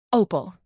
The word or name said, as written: opal